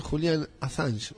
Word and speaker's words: Julian Assange: Otro debate, porque podría ser el señor Julian Assange. ¿Lo dije bien? – Sí, lo dijo perfecto.